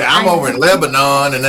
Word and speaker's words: Lebanon: And as they begin to migrate out and the music community starts to collect around Murfreesboro, around Mount Juliet, around Lebanon, you know, and all these other places. It's pronounced Lebanon in Tennessee. It's Lebanon over across the ocean.